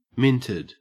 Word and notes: minted: Australian English

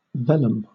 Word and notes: velum: British English

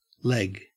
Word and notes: leg: Australian English

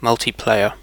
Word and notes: multiplayer: British English

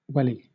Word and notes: welly: British English

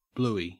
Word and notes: bluey: Australian English

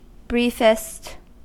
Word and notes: briefest: American English

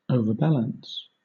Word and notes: overbalance: British English

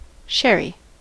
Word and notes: sherry: American English

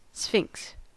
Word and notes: sphinx: American English

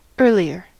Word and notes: earlier: American English

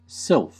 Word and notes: sylph: American English

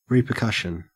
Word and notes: repercussion: Australian English

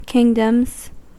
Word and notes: kingdoms: American English

